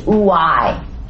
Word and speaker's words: ouai: But the last three trumpet judgments are labeled as woes, and woe in Greek is pronounced ouai, ouai.